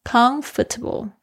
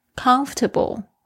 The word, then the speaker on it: comfortable: Comfortable 这边字，这个注意一下，我有很多的学生他们念不好这一个字，会变成 comfortable，重音跑到后面去又听起来怪怪的，重音是在第一个音节上面， comfortable comfortable，后面那个虽然有一个 table，但是你不要念成 table，不是 comfortable， comfortable table。